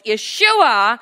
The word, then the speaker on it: Yeshua: Hebrews 1:2, that was now given to him so that now he, in the name Jesus in English, but Yeshua in the original name, the pronunciation goes Yeshua, that every knee should bow, every tongue confess.